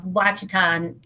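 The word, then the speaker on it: Ouachita: It's called Wachita. Mountains, but I call them Ouachitas because that's what the locals call it. – Oh, okay. Okay, that's cool. – Yeah, they pronounce the O.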